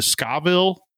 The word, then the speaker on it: Scoville: Am I saying this right? The Scoville. – Yep.